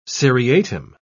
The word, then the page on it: seriatim: British English